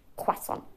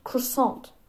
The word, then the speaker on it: Croissant: Und Zendaya und Jacob Button, die mit Tom Holland Spider-Man gedreht haben, sagen auf Englisch Croissant. Es heißt nicht Croissant, es heißt Croissant. Und Tom Holland ist näher an der richtigen Aussprache dran. Sagt aber Croissant, nicht Croissant.